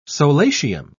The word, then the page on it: solatium: British English